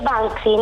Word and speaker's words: Banksy: Per quella roba lì che non so mai come si pronuncia. Come Banksy, che nessuno sa come si dice. Glielo faccio sentire. – Si dice Banksy, non si preoccupi.